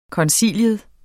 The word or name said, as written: koncilium